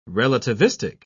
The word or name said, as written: relativistic